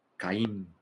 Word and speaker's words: Chaim: Sí, se escribe Chaim, pero en realidad se pronuncia como K. Caim. ¿Sí?